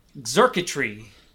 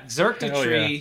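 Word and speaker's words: Xurkitree: Yeah, Xerkitry, yeah. – Which is spelled with an X to make it just annoying. Xerkitry, yeah, it's an electric Pokemon.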